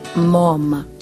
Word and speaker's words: Moama: Moama, across the river. In our language, most times we put the emphasis on the first syllable, so this word would have been pronounced and is pronounced Mom, which is the place of the dead.